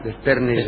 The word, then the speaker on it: desternillar: Anoche, con el tema del matafuegos y Barton leyendo las explicaciones de su uso, me destornillaron de risa. ¿Se dice así? ¿Dice no. ¿Desternillar o destornillar? – Desternillar.